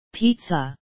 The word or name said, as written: pizza